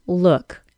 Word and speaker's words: look: The word look, L-O-O-K, is pronounced with what is called the other U sound, look. The other U is also the vowel sound in the word put.